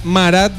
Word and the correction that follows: Murat: No, no, no, Murat. – ¡Ah! Murat Bayaral. – Sí, eso, Murat Bayaral.